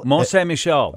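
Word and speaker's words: Mont Saint-Michel: And then Mount St. Michel. – Mont St. Michel.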